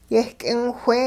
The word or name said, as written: yeh-k'ingwhee